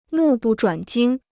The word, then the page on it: 目不转睛: mù bù zhuǎn jīng